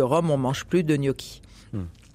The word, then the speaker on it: gnocchi: Euh, le poisson blanc en bouillon de thé il est gnocchi. gnocchi, c'est ça, ça se prononce comme ça Gnocchi. gnocchi. Euh, les gnocchi étant, euh, enfin, fabriqués à base de pommes de terre.